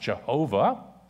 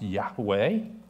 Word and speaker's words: Yahweh: For many years, scholars believed the name should be pronounced Jehovah, modern scholars believe it should be pronounced Yahweh.